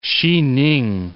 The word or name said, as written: Hsi-Ning